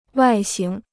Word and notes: 外形: wài xíng

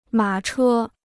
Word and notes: 马车: mǎ chē

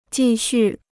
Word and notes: 继续: jì xù

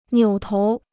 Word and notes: 扭头: niǔ tóu

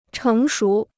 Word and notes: 成熟: chéng shú

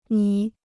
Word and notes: 你: nǐ

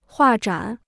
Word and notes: 画展: huà zhǎn

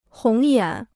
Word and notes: 红眼: hóng yǎn

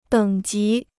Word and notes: 等级: děng jí

